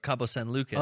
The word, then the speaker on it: Cabo San Lucas: A lot of people thought I would have gone to the Bahamas instead of Cabo San Lucas.